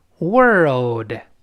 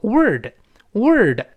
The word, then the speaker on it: world: world，哎，好多人容易把它误读成 word，word 那就成单词那个词了，那个 l 的声音就没了啊。